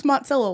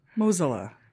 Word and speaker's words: Mozilla: I really thought it was Mozilla, like mozzarella. – There's no T. – There's no T in mozzarella either, but there are two Zs, which changes how you pronounce the Z sound. Anyway, Mozilla makes a lot more sense. – Like rhymes with Godzilla.